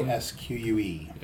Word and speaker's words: Tarrasque: Tarrasque. – Am I pronouncing it correctly? Tarrasque. – Yeah.